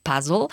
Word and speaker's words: puzzle: una ascoltatore giustamente mi segnala che in realtà in inglese puzzle è una parola inglese e si pronuncia puzzle.